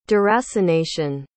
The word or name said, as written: deracination